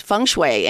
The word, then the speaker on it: feng shui: Right, feng shui.